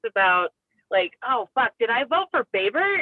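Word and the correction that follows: Boebert: Is that how you say her name? – No. – Babert? – Bobert, I think. – It's Bobert.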